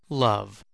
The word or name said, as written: love